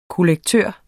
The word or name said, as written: kollektør